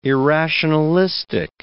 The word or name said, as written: irrationalistic